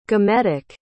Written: gametic

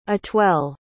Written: atwell

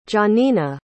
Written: gianina

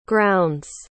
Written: grounds

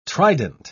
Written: trident

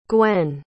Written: gwenn

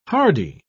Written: hardy

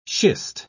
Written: schist

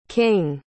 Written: kyng

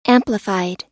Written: amplified

